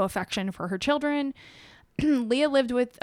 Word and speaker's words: Leah: Leia? – It's spelled Leah, L-E-A, but it's pronounced Leia.